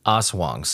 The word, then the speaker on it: Aswang: But after the documentaries that I watched and the books that I rented, and it looks like the people there in the Philippines, they pronounce it Aswang.